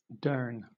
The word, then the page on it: durn: British English